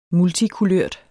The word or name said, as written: multikulørt